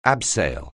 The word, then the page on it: abseil: anglais